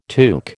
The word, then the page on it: tuque: American English